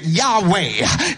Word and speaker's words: Yahweh: His name is spelled in the Hebrew W-H-Y-H. We pronounce it Yahweh in the Hebrew, Jehovah in the English.